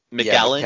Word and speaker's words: McKellen: Is it McGillen or McKellen? I can never pronounce his last name. – Ellen. – McKellen. – Yeah. McKellen.